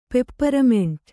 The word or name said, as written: ಪೆಪ್ಪರ್ಮಿಂಟು